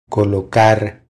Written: Colocar

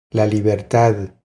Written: La libertad

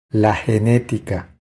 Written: La genetica

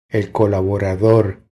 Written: El colaborador

